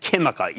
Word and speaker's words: Química: That's called Sociedad Química, even though it's spelled Q-U-I-M-I-C-A, which is a big Chilean materials company with significant lithium exposure.